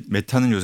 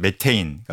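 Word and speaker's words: methane: 예, 이게 메탄은 요새 고등학교 교과서에서는 메테인, 그러니까 미국식 발음으로 한다고 하는데요.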